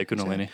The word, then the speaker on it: Kundalini: Say that word, Kundalini? – Yeah, Kundalini. Yeah.